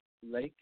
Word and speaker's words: Laich: I'm not really sure, but his name is Brooks Legge, if I'm saying it right. – Mm-hmm. Well, how do you say married? Well, it's spelled L-A-I-C-H. How would you pronounce that? – L-A-I-C-H. Lake? I'll say Lake.